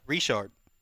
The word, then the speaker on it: Richard: So we got Taiwan Jones, DeAndre Washington, and Jalen uh, Richard. Is that how you pronounce mm-hmm. that? – Richard. Richard. Let's let's calm down with that depth chart.